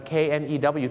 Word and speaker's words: knew: k n e w ที่แปลว่ารู้นะฮะในอดีตนะครับ I knew her โอเคอันนี้นะครับจะมียกเว้นนิดนึงครับฝรั่งบางทีก็จะออกเสียงว่า I knew her นะครับบางคนจะออกเสียงสระอูนะครับ I knew her หรือว่า I knew her นั่นเองครับ